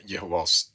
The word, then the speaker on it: Jehovas: Men egentligen om man ska säga som de säger, så är Jehovas vittnen, för betoningen är på Jehova, han var med Jehovas vittnen, och det var några saker som jag lade märke till där.